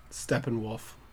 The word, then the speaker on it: Steppenwolf: Steppenwolf.